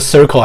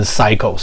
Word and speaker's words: circle: 但是我跟你说，就算你读到博士，我去听一些人的博士生等，他的 circle 还可以念成 cycle。